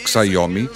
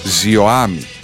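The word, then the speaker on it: Xiaomi: Τι ζιωάιμι, ζιωάμι, προσφέρετε, όχι ξαϊόμι, ζιωάμι.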